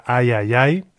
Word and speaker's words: Ai Hai Hai: Ahí teníamos a Antti Palanen con esta pieza titulada Ai Ai Ai. En realidad se escribe Ai Hai Hai.